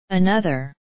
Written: another

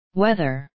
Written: weather

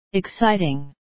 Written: exciting

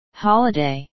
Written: holiday

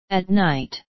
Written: at night

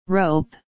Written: rope

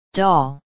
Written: doll

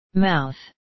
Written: mouth

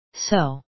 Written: so

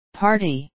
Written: party

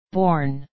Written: born

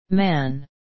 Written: man

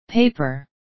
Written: paper

